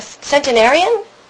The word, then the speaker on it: centenarian: Okay, I'm here with Norman Corwin, who is a centenarian? How do you say it? – Centenarian.